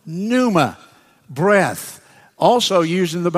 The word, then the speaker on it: pneuma: Pneumonia, P-N-E-U-M-A. That's the same Greek word, pneuma. It's pneuma, pneuma, that P is silent.